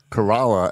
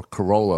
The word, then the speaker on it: Corolla: So the way the Outer Banks are, Kerala, as the people say there, or Corolla, like everyone else says. – Right. – So they said they pronounce it Kerala. – They do. Even though it's spelled like the car.